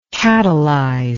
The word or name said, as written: catalyzer